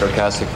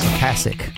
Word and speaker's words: Ocasek: A lot of people are confused about how his name's pronounced. – Hi, this is Rick Ocasek from The Cars. – Ocasek. Got to say with an American accent.